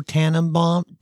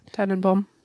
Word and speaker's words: Tannenbaum: I enjoy O Tannenbaum. I said that right. – Yeah. – Tannenbaum.